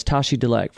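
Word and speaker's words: Tashi Delek: And the common greeting amongst Buddhist people there is Tashi Dalek, four syllables.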